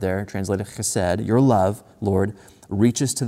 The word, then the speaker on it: chesed: You got to like, if you're going to say that right, it's chesed.